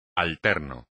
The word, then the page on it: alterno: espagnol